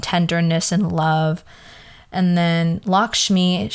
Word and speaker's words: Lakshmi: Yes, Lakshmi.